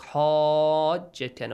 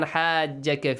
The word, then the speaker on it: حَاجَّكَ: فَمَنْ حَاجَّكَ. فَمَنْ حَاجَّكَ, right? – Yes. – So this is mandatory. And also don't pronounce it like حَاجَّكَ, no. Ha, which has a light sound.